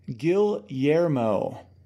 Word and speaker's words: Guillermo: Hang on, he gave me a better pronunciation. It is Emil Guillermo. Because he's Filipino. Guillermo is Hispanic. The double L is pronounced differently for Filipinos.